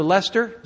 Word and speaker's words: Leicester: Leeds, and then I never pronounce it right, but Leicester, is that right? Yeah.